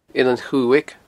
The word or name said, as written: Eilean Chuaig